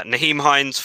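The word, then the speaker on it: Nyheim Hines: Is it Naheem? Naheem Hines? Yeah. Naheem Hines, 44 yards.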